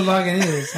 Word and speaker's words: Lagunitas: No, Lagunitas. – Lagunitas? – Lagunitas. – Okay. – I knew some dude, he was of your nationality, he used to call it Lagunitas.